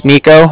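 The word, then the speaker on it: Niko: Like I said, my name's Nico. That's N-I-K-O.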